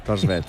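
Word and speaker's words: Thorstvedt: Sí, de Tors Belt, si lo he pronunciado bien. – Tors, sí, absolutamente. – Torsbelt.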